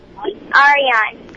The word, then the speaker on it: Arian: Hello, my name is Aryan, and I think the answer is a teapot. – And this Aryan? – Aryan. – How do you spell it? A-R-Y-A-N? – A-R-I-A-N. – A-R-I-A-N.